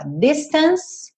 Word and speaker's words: distance: Distance termina na letra E, mas a letra E não é pronunciada. Então, você repara que o último som dessa palavra fica sendo s, que é o som da letra C aqui, the distance.